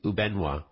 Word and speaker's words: Ubenwa: For our listeners, it's spelled U-B-E-N-W-A, Ubenwa.